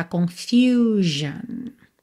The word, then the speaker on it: confusion: Já na segunda, confusão, confusion. A letra U tem esse som U, tá?